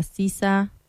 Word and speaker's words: SZA: Se escribe SZA, se pronuncia SZA.